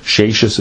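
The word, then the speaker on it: Seixas: One is Gershon Mendesheishis, that's S-E-I-X-A-S, Sheishis it's pronounced.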